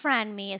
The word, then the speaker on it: Franmi: My name is Franmi. It's spelled F as in Frank, R-A-N-M-I.